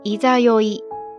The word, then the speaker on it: 十六夜: 简单来说，十六夜、十六夜还可以读作いざよい、いざよい、いざよい。